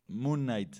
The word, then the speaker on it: Moon Knight: Ya para el 2022, pues viene Guardianes de la Galaxia Holiday Special, She-Hulk, Moon Knight, ¿sí se pronuncia así bien? – Moon Knight, sí.